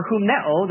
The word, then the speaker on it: humneo: H-U-M-N-O-S. Humnos is the noun form, or humneo, the verb form, H-U-M-N-E-O. And again, a long mark over the O, showing it's omega, pronounced O, not A.